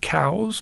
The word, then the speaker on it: Coues: Coos is spelled C-O-U-E-S. It's in honor of a naturalist named Elliot Coos. I was reading that actually the family pronounced it cows, but anyway, you know, it's Americans, we just changed the name, coos.